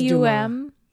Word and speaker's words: Dumas: Dumas. I'm not pronouncing the S. Is that right? – I think it is Dumas.